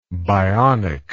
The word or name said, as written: bionically